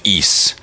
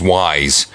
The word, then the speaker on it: Ys: from Ys. Well, you might know it as Wise. Oh yeah, because okay. You, because you, eh, but you pronounce the Y as an E, as in uh, Spanish.